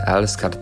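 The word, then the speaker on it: Älskar: Pisze się Elskar, to pierwsze A jest z kropeczkami, skąd właśnie taki... zamiana na E.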